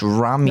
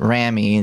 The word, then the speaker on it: Rami: It's actually, it's pronounced Rami. No, it's Randy? – Rami, actually. The reason I know this is because his brother is famously named Sammy. And he said in an interview that Sammy rhymes with Rami, and so everyone always has called him Rami, but his name is actually Rami.